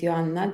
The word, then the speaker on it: Joanna: So, as you already know, uh, my name is my name is Joanna. This is actually how you pronounce my name in my English, uh, in my language.